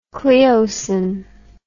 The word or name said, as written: Cleocin